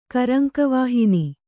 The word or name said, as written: ಕರಂಕವಾಹಿನಿ